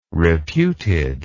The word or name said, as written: reputed